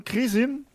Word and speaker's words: Kresin: Und bei uns ist der äh, Stefan Krisin. Ich hoffe, den Nachnamen habe ich jetzt richtig ausgesprochen. Perfekt.